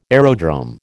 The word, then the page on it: aerodrome: American English